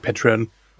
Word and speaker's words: Patreon: Soll ich eigentlich auch Patreon sagen oder Patreon oder wie ist denn die offiziell richtige Aussprache für Deutsche? Ich würde trotzdem sagen, es ist Englisch, weil es ein englisches Unternehmen, ne? Also ein amerikanisches Unternehmen. Also würde ich schon sagen Patreon.